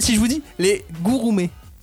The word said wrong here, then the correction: gourmet: Les ah. gourmets, prononce-le très vite. gourmet.